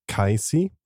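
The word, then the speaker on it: Cayce: Mike also highlighted that the Sleeping Prophet's name is probably pronounced Kaisi.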